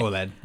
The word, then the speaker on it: OLED: Is it OLED? Is that how you say it? – Yeah, okay. OLED.